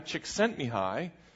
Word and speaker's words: Csikszentmihalyi: His name is Mihai Csikszentmihalyi, And it sounds simple, but it's 24 letters, and none of them are vowels in the original.